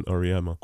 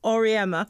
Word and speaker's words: Oryema: um, is that how it's pronounced? – Uh, you can choose. – No, that. no, no, no. Tell me how you would like it to be pronounced because I'm fussy about my name. So okay, Wilson Oryema. – Oryema, Oryema.